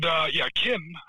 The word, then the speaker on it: Qin: And Qin, I think is how you pronounce it, Q-I-A, because there's no U in there.